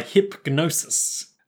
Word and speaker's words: Hipgnosis: Hipgnosis. – Okay, G is silent there, but I wanted to pronounce it so that if any enterprising listeners wanted to look it up, they wouldn't just type Hipgnosis.